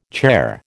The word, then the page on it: charr: American English